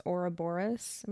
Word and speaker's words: Ouroboros: Okay, so the carver tells them that if they retrieve this Ouroboros, am I saying that right? – Mm-hmm.